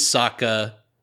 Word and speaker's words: Sokka: He says, yeah, my name's Sokka. It's pronounced with an Akka.